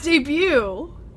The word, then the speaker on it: debut: You don't pronounce the T. – I've been saying it wrong my entire life. – Really? – Yeah. – Yeah, it's debut.